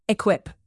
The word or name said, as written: equip